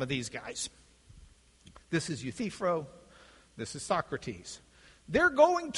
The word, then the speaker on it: Euthyphro: which is interesting because a lot of them seem to pronounce it Euthyphro, but that's not the way you'd pronounce it if they read Greek. It's Euthyphro. Emphasis is on the center syllable. Euthyphro.